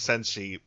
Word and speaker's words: Senshi: Yes, because I'm not confident in my pronunciation of Senshi, even though Kitty Hawk tells me I just said it just fine.